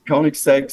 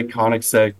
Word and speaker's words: Koenigsegg: Koenig, I recognize that as German for king, I think. And then egg. Koenigsegg, is that what it is? – Yeah, Koenigsegg. Some people say Koenigsegg, but it's a Swedish vehicle.